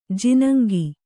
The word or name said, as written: ಜಿನುಂಗು